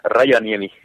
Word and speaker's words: Rajaniemi: You tell everyone, then, how do you pronounce it? – Ranyani.